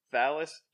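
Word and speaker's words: Talos: um, our uh, uh, opening here, our uh, opening splash page, we have the haunting of Thalos. Do we all agree on that? Is the uh, pronunciation? – Yes.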